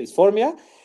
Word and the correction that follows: dismorfia: este, es dismorfia, perdóname.